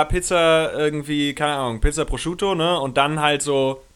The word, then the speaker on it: Prosciutto: Ich hätte voll Bock auf eine fucking Pizza Prosciutto gehabt. So, jetzt habe ich es richtig ausgesprochen. – Ja. – Prosciutto.